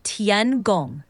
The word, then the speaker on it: Tiangong: The name of China's space station is pronounced Tiangong. In Mandarin, Tian is like Tien, with a soft T sound and a rising tone, and Gong is like Gong with a level tone, similar to the word for a large bell.